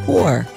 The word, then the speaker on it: Thor: Which, by the way, I have a nephew whose name is Thor, but it's pronounced Tor.